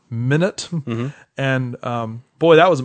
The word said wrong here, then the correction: Minot: I mean, we had just people, person upon person, just emailing us saying, it's pronounced Minot. – You know, it rhymes with why not.